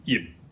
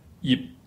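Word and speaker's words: yip (葉): Let's look at the first character, ye, meaning in Mandarin, leaves, but it's pronounced in Cantonese and Middle Chinese as yip. And you can hear again, yip. In order to get this word out, you have to close your mouth to get the P sound, but you do not pronounce it.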